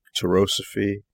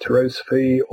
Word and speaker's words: Tarosophy: Am I pronouncing that correctly? I just want to make sure. – I believe so. It's it is a generated word. It's also a trademarked word. And the story behind its generation is interesting. We might cover that later. However, it could be pronounced Tarosophy or Tarosophy, depending on which side of the Atlantic you may be.